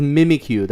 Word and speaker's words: Mimikyu: It's Mimikyu. That's how you pronounce his name.